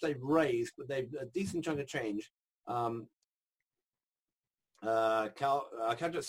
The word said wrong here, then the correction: Kuldeep: Singh Rajput. God, I hope I got his name right. Kuldeep Singh Rajput. Sorry, Kuldeep, for screwing up your name.